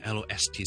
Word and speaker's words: lost: 好啦，发音点教呢？Lost 咧，L O S T 失去咧，我先读 lost 先，好似 L O S S 咁，lost，跟住上去个 T M lost，好容易啫呢个 lost。